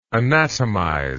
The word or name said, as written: anatomizer